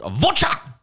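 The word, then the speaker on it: Werchter: Should I say Worcester sauce? – It's actually pronounced Wurtcher.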